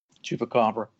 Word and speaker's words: Chupacabra: the Chupacabra? – Chupacabra, yeah.